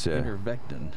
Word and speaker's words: ivermectin: All right, Ivermectin, isn't it? – Very much being talked about these days. – Ivermectin. – Yeah, I think ivermectin. It's pronounced Iver.